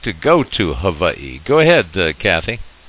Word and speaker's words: Hawaii: All right, we've got Hawaii from, actually that's pronounced Hawaii, incidentally, to be politically correct. The W's in the Hawaiian language are pronounced as a V instead of a W.